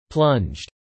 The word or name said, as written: plunged